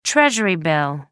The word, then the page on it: treasury bill: American English